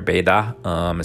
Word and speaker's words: Beida: You have Beijing University, or Beida, is how they pronounce it there.